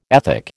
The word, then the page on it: ethic: American English